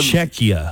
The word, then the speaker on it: Czechia: Did you say Kazakhia? – Yeah, I don't know how to pronounce it. Kazakhia. Czechia? – Czechia. It's just like we said Czechia.